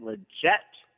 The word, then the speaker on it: Lletget: Remember, it's Sebastian Lletget.